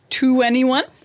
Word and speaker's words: 2NE1: 2NE1.